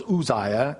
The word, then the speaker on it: Uzziah: In the year that King Uzziah died, and that's Uzziah. I know it's spelled with a U, but in the Hebrew it's a U, so it's Uzziah.